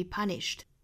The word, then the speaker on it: punished: Punished. 尾部 ed 依然是要读成轻辅音的 t, punished, punished.